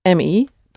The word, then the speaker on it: me: The word for me is spelled the same, M-E, but pronounced me, me. The E's at the end of Dutch words are always unstressed.